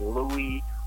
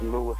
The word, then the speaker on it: Lewis: I get all these different pronunciations. So I'll get Louis or Luis And I'm just, I don't, you know, I just want to clarify that Wisin for All is pronounced Lewis, and I'm going to spell it L-E-W-I-S, because that's more of a common spelling of Lewis.